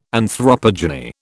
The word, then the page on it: anthropogeny: British English